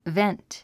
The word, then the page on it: vent: vént; ベェントゥ